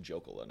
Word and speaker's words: Jokela: That's actually the traditional pronunciation, but we've Americanized, okay. And it's just Jokela now. – Jokela. – So we changed the J to, or it used to be a Y.